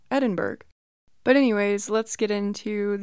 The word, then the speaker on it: Edinburgh: I think I pronounced it Edinburgh, but that is not right at all. It's Edinburgh. So thank you,